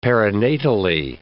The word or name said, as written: perinatally